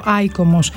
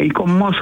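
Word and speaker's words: ICOMOS: Αν δεν το γνωρίζετε, αυτό ακριβώς κάνει το ΙΚΟΜΟΣ ή το, δεν ξέρω αν πρέπει να το πω, ΑΙΚΟΜΟΣ. Πρώτα απ' όλα, καλησπέρα σας. – Καλησπέρα σας. Ναι, ΙΚΟΜΟΣ θα το λέγαμε, γιατί έτσι προφέρουν όλο με τον γαλλικό τρόπο.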